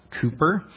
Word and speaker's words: Cowper: Looks like Cowper. It's pronounced Cooper.